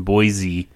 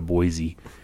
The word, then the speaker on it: Boise: Yeah, decided to go to Boise. Is he going to be mad that I say Boise? – Probably. – Yeah. – I'll call it like Boise. – Boise. in Bois, the S is silent.